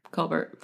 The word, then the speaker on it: Colbert: Colbert said all sides are good at this point.